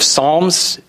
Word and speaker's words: Psalms: If you go to the Old Testament, to the book of Psalms, and if you're kind of unfamiliar with the Bible, it starts with a P. I know it's weird, the P is silent.